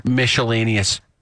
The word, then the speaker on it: miscellaneous: Miscellaneous. – You put a shh in miscellaneous.